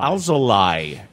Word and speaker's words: Alzolay: Alzolay. L-A-Y. Because that should be the phonetic Spanish pronunciation. Wouldn't be lay. It would be lie.